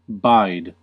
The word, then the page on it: bide: /baɪd/